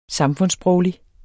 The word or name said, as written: samfundssproglig